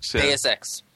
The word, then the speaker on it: Deus Ex: Series, the how do you say that? Deus Ex or whatever? – Deus Ex.